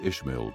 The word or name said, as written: Ishmael